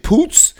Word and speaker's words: Poots: Imogen Poots. – Is it Poots? – Yeah, that's how you say it.